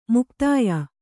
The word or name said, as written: ಮೌಗ್ಧ್ಯ